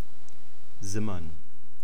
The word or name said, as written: simmern